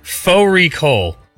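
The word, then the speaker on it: fårikål: Faux recall, which is a mutton and cabbage. It's spelt like Fari call.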